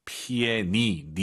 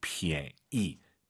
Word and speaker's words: 便宜: 피에 니, 니은 발음 들리면 안 돼요. 피에 이, 피에 이, 피에 이. 오히려 그 피엔 발음에서 엔, 니은 발음이 약간 무금된다는 느낌으로 피에 이. 이것도 좋을 것 같습니다. 피에 니, 이렇게 하지 마시고요. 피에 이.